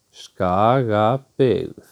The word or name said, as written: Skagabyggð